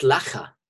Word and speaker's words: Lecher: Ernst, I'm probably mispronouncing his name, Ernst Lecker, L-E-C-H-E-R. – Interesting. No, Ernst Lecker.